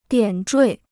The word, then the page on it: 点缀: diǎn zhuì